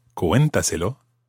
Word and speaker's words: cuéntaselo: There's an accent on the E of cuéntaselo. Because, of course, we need to keep the stress on that syllable, cuenta. We couldn't say cuéntaselo or cuéntaselo or anything like that. Cuéntaselo, it has to maintain the stress on the cué, cuéntaselo syllable. So we put a written accent.